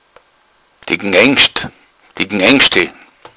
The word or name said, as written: gneingschde